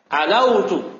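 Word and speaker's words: Alautu (علوت): Maka antum bacanya apa? Alautu. Alautu. Jangan kasih tasdid wawnya.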